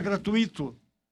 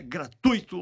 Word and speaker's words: gratuito: Para terminar essa dica de hoje aqui, outra palavra que se ouve muito por aí todos os dias é a palavra gratuito, que 90% ou 90% ainda pronuncia gratuito. É, talvez esquece, né? Mas é, a pronúncia correta é gratuito ou gratuita.